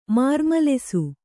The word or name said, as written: ಮಾರ್ಮಲೆಸು